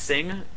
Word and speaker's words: Cing: Or, you know, another, you know, everything that came out of, I think it's pronounced Sing, C-I-N-G, yeah.